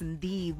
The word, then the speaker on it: Ndivho: My name is Ndivho Makhwanya. It is spelled out as N-D-I-V-H-O.